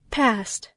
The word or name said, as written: past